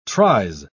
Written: tries

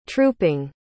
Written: trooping

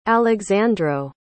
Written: alexandro

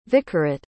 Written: vicarate

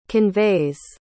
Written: conveys

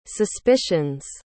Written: suspicions

